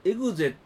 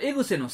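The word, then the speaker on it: EXE: ロマンスカーエグゼと読むと小田急ファンの方からお叱りを受けるのでご注意ください、あ、そうそう、エグセって書いてたんですよ、ロマンスカーエグセアルファ、このエグセのセをゼと読むとあかん、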